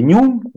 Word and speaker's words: NYM: В России устоялось обозначение нюм, вот, и, соответственно, NYM, если мы говорим а, по-английски произносим, вот именно эти три буквы, латинские, uh-huh. английские.